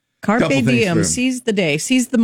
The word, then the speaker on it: carpe diem: Uh, and then it's uh, carpe diem. Is that how you say it? carpe diem. – Carpe diem.